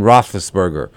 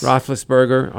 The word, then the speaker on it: Roethlisberger: Maybe we'll touch on Tiger Woods - Ben Roethlisberger. Did I get his name right? – Yes. – Roethlisberger.